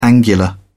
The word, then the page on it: angular: British English